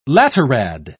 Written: laterad

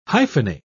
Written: hyphenate

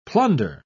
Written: plunder

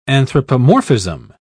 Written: anthropomorphism

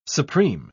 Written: supreme

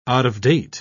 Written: out-of-date

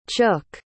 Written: chook

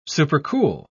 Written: supercool

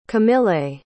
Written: camillei

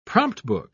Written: promptbook

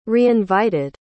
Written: reinvited

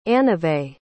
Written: anevay

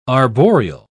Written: arboreal

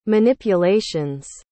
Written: manipulations

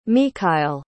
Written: michail

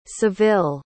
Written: saville

